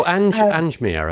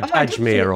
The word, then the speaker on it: Ajmer: Oh, Anjmer, I think it is. – Anjmer. – Ajmer or something. Ajmer? Yeah, okay.